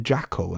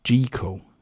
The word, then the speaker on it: Jekyll: It's not Jackal and Hyde, what people think it's Jekyll rhymes with treacle, jeekle and hide. That's the proper pronunciation of it.